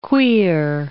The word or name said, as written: queer